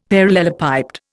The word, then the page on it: parallelepiped: Indian